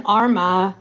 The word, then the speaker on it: Armagh: It's Sligo, Mayo, and Galway. Then Armagh, Tyrone, and Offaly. And then Leitrim, Donegal, and Laois.